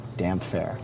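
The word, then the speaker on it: Damphair: How do you pronounce Aaron Greyjoy's title? Damp hair. That's from Rob. – Damp hair. Yes. – Okay, damp hair.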